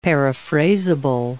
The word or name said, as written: paraphrasable